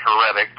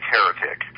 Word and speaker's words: Heretic: A lot of people say Heretic, but it's Heretic.